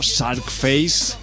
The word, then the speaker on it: Czarface: Un proyecto bastante comiquero, bastante serie B, bastante de cine de género, llamado Shark Face, y lo de Shark Face se escribe como C-Z-A-R-F-A-C-E.